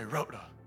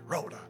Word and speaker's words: Rhoda: Say it like that. Say Rhoda. Roll that R. Sounds a little dramatic. Rhoda.